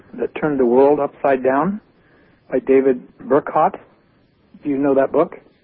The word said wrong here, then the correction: Bercot: Yeah, I think his name might be pronounced Bersault, David Bersault. – B-E-R-C-O-T.